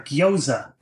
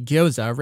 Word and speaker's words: gyoza: If you go to a Japanese restaurant, you will probably see gyoza written out, G-Y-O-Z-A, referring to dumplings. And so they would, if they saw that word, that is how they would instinctively pronounce it. But you have that hiragana indicating, no, please pronounce this as the Chinese.